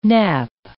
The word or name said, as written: nap